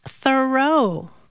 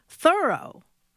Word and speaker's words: Thoreau: All this time, I thought his name was pronounced Thoreau, but no. – How is it pronounced then? – Thoreau. – Thoreau. Like, thoroughly did it.